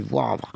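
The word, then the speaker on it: Woëvre: Oui, c'est W-O-E-V-R-E. – On aurait tendance à prononcer Voivre, mais en fait, on prononce dans la région, on dit Voivre.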